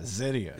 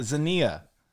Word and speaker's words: Xenia: Darren Bailey's from Xenia. – Yeah, Zinnia. – Is that how we decided we're going to... – Zinnia. – Is that how we decided? – Zinnia.